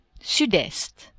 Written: sud-est

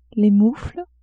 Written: moufles

